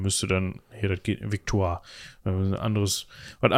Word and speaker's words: Victoire: So, und das war eben Edward Augustus, war Vater von Victoria und er hat seine Tochter Victoria zusammen gehabt mit Victoire von Sachsen-Coburg-Saalfeld. So würde ich sie jetzt mal aussprechen. Ich würde das jetzt mal französisch aussprechen. – Äh, kann auch Victorie sein.